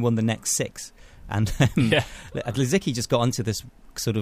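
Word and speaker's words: Lisicki: I just read this article that said that she's German and it's Zabina with a Z and it's Litsicki because it's obviously a Polish name in origin or something like that.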